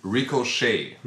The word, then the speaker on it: Ricochet: Wie wird das ausgesprochen? Ricochet? Ricochet. Ricochet, also. Ricochet. der englische Begriff.